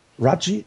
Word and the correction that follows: Rajai: Rajai, Raji Davis. – Rajai. – Rajai.